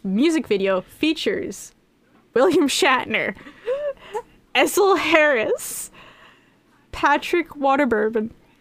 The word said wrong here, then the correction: Warburton: Patrick Warburton.